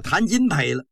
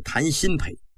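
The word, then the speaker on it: 谭鑫培: 今儿个是谈新陪唱的文昭关，他不认识新字，把这个新字念成金了，叫谈金陪了。光绪微微的一笑，是谈新陪，不是谈金陪。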